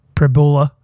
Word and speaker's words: Pribula: So I care more about Pribula. – Rhymes with fibula.